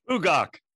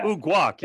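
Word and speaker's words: Uguak: How do you say his last name? – Ugok. – Ugok. – Ugok. – Yeah. – Ugok, yes.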